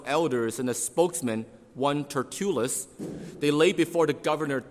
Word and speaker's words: Tertullus: And so we're introduced a character called Tertullus.